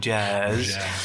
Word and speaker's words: Jazz: En op deze dag die jij beschrijft, komt er een vriendje van Ellen spelen jazz. Zo spreek je het ook uit, hè? Langgerekt eh, staat er in het boek, jazz.